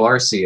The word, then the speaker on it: Arceus: I'm not sure how to pronounce this, but it's Arceus? – Yeah, I believe so. Arceus.